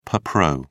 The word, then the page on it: per pro: British English